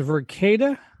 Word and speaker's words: Verkada: So anyway, we're actually going to be talking about, is it pronounced Verkada? – I think so, yeah.